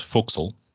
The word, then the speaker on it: forecastle: There are other nautical terms that do the weird pronunciation too, like forecastle being pronounced fo'c'sle.